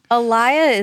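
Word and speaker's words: Alaïa: I don't even know how to say his last name. A-L-A-I-A. – Oh, Alaya. Yeah.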